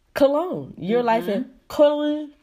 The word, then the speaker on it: Köln: It's so hard to pronounce it because it's K-O-L-N in German. Right, and it's the U that gets people, Köln. The umlaut, the O with the two dots.